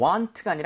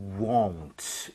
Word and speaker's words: won't: 자, 그리고 won't 할 때는 입모양을 많이 모아야 돼요. 그래서 you want가 아니라 you won't 이렇게 꼭 발음하시길 Won't.